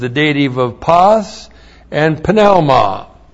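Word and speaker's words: pneuma (πνεῦμα): Now, you say pneuma, but no self-respecting Greek would ever say pneuma. There are no silent letters in Greek. It's pneuma. So we have the verb pistuo. P-I-S-T-E-U long O.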